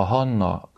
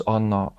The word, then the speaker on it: Hanna: A mi megszokott kiejtésünk az általában a héber bibliának a görög fordításához, illetve a görög fordításnak a latinizált formájához igazodik, és a Hanna közelebb van a héber kiejtéshez, az Anna az pedig közelebb van egy latinizált formához, amelyikből már mondjuk, hogy az olaszokra gondolunk, ők egyáltalán nem ejtenek ki hát, ahonnan ez a H betű már elveszett.